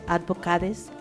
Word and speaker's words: Advocates: International Rights Advocates, ¿cómo se dice? – Advocates.